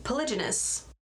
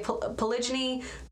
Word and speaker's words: polygyny: who are polygynous. Polygynous. Polygynous. I am not a pronunciation scientist. It's not polygamy, okay? Polygyny, polygyny is different from polygamy.